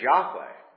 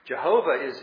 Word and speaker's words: Yahweh: That's not a real name. His name is Yahweh. And that's the correct understanding, the correct pronunciation of it. Jehovah is a mesh of Yahweh and Elohim that's just shoved together to get people to not say Yahweh.